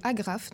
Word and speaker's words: Agraf: Alors, moi, mon plan cul, c'est la chaîne YouTube de Agraf. Donc, ça s'écrit comme une agrafe.